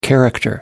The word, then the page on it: character: American English